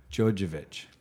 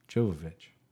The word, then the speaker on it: Jovovich: Jovovich.